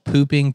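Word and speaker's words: Phu Ping: Like once you're close with them, like so in Thailand in Chiang Mai, there's a there's like a palace called Pooping Palace. That's literally how you say it. It's like Poo Ping Palace.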